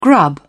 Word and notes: grub: British English

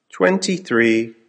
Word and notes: twenty three: American English

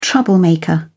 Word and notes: troublemaker: British English

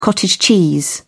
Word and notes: cottage cheese: British English

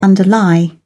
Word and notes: underlie: British English

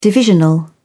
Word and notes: divisional: British English

